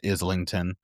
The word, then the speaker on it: Islington: Somebody called him Islington the other day, and I refuse to believe that's how it's said. I'm pretty sure it's Islington.